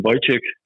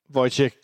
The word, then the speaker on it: Wojciech: Ja, jeg tror, at Danmark er det et mellemnavn, fordi at rent juridisk skal det være, men ja, det er bare simpelt Wojciech. – Wojciech, godt. Så, ja. så er jeg reddet af din simple udtale.